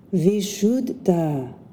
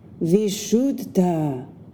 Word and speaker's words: Vishuddha: The name of the throat chakra in Sanskrit is pronounced Vishuddha, Vishuddha.